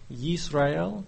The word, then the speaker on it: Israel: I'm not Hebrew, but the word we pronounce Israel is something like Yisrael.